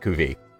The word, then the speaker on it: cuvée: Is it pronounced cuvée? – Yes. In Stephanie language, it's pronounced cuvée.